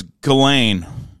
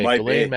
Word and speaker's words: Ghislaine: It's like, how do you say her first name? Ghislaine or something. – It's I heard it was Ghislaine. – Okay, Ghislaine Maxwell. – But people say Ghislaine, which is more fitting.